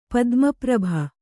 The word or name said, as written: ಪದ್ಮಪ್ರಭ